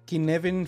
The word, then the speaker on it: Cynefin: que se llama el Kinevin Framework o el marco de trabajo eh, Kinevin que se, que se escribe como Cinefin para aquellos que a lo mejor eh, lo hayan visto por ahí escrito.